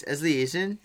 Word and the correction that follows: Elysian: Elysian. – Elysian.